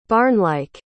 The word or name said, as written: barnlike